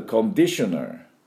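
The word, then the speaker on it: conditioner: あと、コンディショナー。で、コンディ、トゥトゥトゥトゥトゥトゥ、ね、マシンガンのトゥトゥトゥトゥトゥ、ドリルで土を穴を開けるようなトゥトゥトゥトゥトゥ、コンディショナー、このディのところにアクセントがあります。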